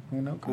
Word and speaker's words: Winokur: um, but talking about Marissa Jaret Winker, I'm sorry if I say her name wrong. – Marissa Jaret, Jaret Winker. – Winker. Winker. Winokur. Well, you say yeah. – Winokur. I think I, I say Winokur.